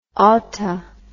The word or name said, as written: oughta